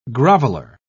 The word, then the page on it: groveler: British English